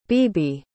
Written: beebe

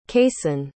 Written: kayson

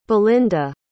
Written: belinda

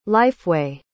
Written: lifeway

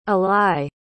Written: alai

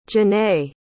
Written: Janaye

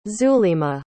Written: zulima

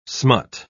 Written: smut

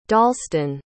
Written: dalston

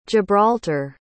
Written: gibraltar

